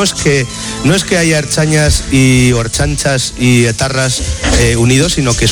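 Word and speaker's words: ertzainas: Por otro lado, que como usted dice, están formados por erchañas y etarras. Es una especie, a mí me recuerda bastante, erchanchas, se dice erchanchas.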